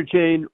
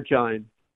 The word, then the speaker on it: Jain: Either Jain or Jain. – Okay, Jain. – Jain is more Hindi, Jain is more Sanskrit.